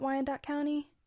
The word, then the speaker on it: Wyandotte County: So as always, we'll start with the history and the facts about Wyandotte County.